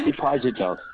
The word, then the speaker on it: Pajitnov: Alexei Pajitnov.